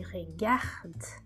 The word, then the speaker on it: regarde: Quando eu digo je regarde, eu tenho gar bem forte e o d fraquinho no final.